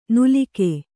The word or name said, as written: ನುಲಿಕೆ